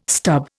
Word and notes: stub: Indian

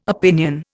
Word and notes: opinion: Indian

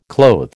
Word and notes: clothe: American English

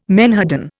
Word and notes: menhaden: Indian

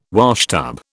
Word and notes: washtub: British English

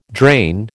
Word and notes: drain: American English

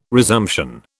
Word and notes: resumption: British English

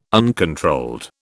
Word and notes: uncontrolled: British English

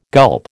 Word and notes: gulp: American English

